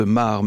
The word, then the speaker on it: Maere: Je pense à vous, le chanteur belge Pierre de Mar. Oui, on dit Pierre de Mar, me dit Isabelle Fiot. Ça s'écrit M-A-E-R-E. Alors, on a tendance à dire Pierre de Mer, mais c'est Pierre de Mar qui sera d'ailleurs en concert à l'Alhambra le 23 janvier 2027.